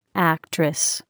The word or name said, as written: actress